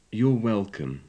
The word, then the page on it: you're welcome: english